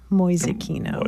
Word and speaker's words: Mäusekino: M-A-U-S-E-K-I-N-O, Mäusekino.